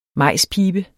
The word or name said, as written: majspibe